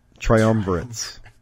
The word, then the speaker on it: Triumvirates: Triumvirates.